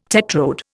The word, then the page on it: tetrode: Indian